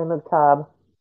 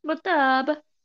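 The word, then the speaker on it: Mukhtab: And the voice of the tribe is it Ali Mc, I don't know how to say his last name. – Ali Muktab. – Muktab. – Muktab, Ali Muktab.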